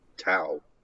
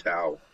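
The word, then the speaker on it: Tião: Well, it's Macaco uh, Tao. – Okay, I'll take it. – Whatever. Pronounced tau.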